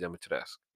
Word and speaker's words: Dimitrescu: For the most part, the way it's pronounced is Lady Dimitrescu.